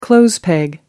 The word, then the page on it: clothes peg: American English